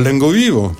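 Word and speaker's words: Lenga Viva: Tu veux dire Lengo Vivo? – Lengo Vivo, voilà, je suis pas d'ici. – Alors ça s'écrit Lengo Viva, mais en occitan, les A de terminaison se prononcent O.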